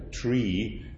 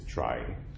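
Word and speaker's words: trie: So they would have preferred to pronounce, they would have preferred it to be pronounced a tree, like in retrieval, but that's very confusing because then you don't know, is he talking about a tree with a double E or is he talking about a tree with IE? And so by now the standard pronunciation is try.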